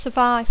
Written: survive

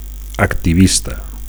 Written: activista

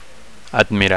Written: admirar

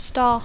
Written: star

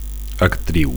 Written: actriu

